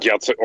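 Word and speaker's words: Jacek: По-моему, даже у Джессика Ласковски, по-моему, я правильно произношу, или если неправильно, поправьте меня. Там уже ну, обновилась доку. он, да. – Яцек, он, видимо, Яцек все-таки, он же поляк.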